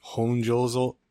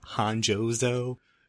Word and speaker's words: honjozo: O sounds in Japanese are O, so it's Honjozo, not Honjozo.